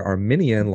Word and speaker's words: Arminian: And for listeners out there, Arminian, like what we are, is spelled with an I. So it's A-R-M-I-N-I-A-N, right? Mm-hmm. That's Jacob Arminius. That's Arminian theology. The other one is spelled with the E, and that is Armenian, like the country, like the people group.